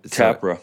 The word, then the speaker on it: Tappara: So you ended up, and correct me if I'm wrong how to say this, is it Tappara? – Yeah, Tappara. So, Tappara, Finland.